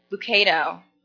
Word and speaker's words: Lucado: How do you say his name, Grandma? Lucado. Max Lucado.